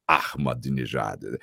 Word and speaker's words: Ahmadinejad: Aí o Franco falava, ó, oh, esse aqui é assim, é árabe, então você tem que aspirar o árabe, então é Ahmadinejad.